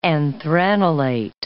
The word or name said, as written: anthranilate